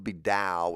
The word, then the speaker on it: Taoism: Dao would be the concept, but the religious, when Westerners studied it, they would say and spell it Taoism, and that's okay. You can say that and nobody should laugh at you. But people say Taoism because they don't want to sound smart the same way they say Kurt Vile instead of Kurt Weill.